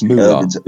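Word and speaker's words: moolah: Not next day, the next cinema visit, because £10 is a lot of, you know, boulard. – Boulard? – Yeah, I don't know, I said that wrong. That's just moulard. Moulard, quite right.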